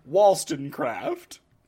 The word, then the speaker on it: Wollstonecraft: Wollstonecraft. – What Holy in the shit. Fuck is that name? – It's That's spelled Wollstonecraft. Wollstonecraft.